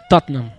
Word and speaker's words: Tottenham: A pronúncia em inglês britânico como que é? – Tottenham. – Tottenham? Tottenham. É, olha aí. Eu achei que ia ser um Tottenham. Né? – Não, é Tottenham, eu...